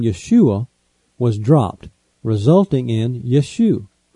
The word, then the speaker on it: Yeshua: After the Babylonian captivity, the Hebrew spelling changed, and the name Joshua, as found in the books of Ezra and Nehemiah, would be pronounced Yeshua.